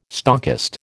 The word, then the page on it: stockist: American English